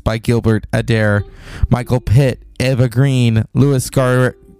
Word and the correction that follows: Garrel: Oh, it's Louis Garrel, who was in Little Women.